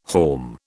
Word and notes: haulm: British English

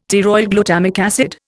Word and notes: pteroylglutamic acid: Indian

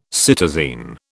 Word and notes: cytosine: British English

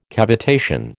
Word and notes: cavitation: American English

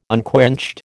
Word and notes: unquenched: American English

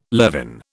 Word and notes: levin: British English

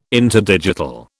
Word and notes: interdigital: British English

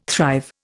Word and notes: thrive: Indian

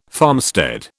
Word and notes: farmstead: British English

